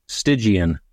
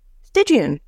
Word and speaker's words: Stygian: I don't know how your guide pronounced it in the audiobook. Stygian? – Oh, Stygian. – Stygian.